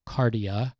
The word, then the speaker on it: kardia: The Greek word is kardia. I know Sandra wants me to spell it. K-A-R-D-I-A. Cardia.